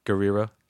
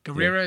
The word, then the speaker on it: Gurira: Gurira. – Gurira, yeah. Is that how you really pronounce it? – Yeah. – Thank you. – Yep. – I never knew how to pronounce it. – Yeah, two R's.